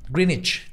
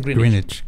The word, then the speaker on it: Greenwich: ajá, no, no es witch. Es como, o sea, de bruja, es como de sandwich. – Greenwich. Greenwich. Greenwich. – Greenwich. Mm. – Greenwich. Pero le dicen Greenwich.